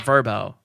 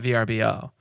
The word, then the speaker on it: VRBO: I just realized the other day that VRBO is pronounced Verbo, not VRBO.